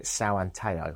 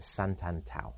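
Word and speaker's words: Santo Antão: And quite often, just a quick note, during this podcast, I will call it São Anteo because I didn't realise how you say it. I now know that the locals call it Santantau.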